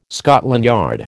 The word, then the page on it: Scotland Yard: American English